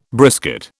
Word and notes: brisket: British English

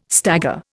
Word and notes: stagger: Indian

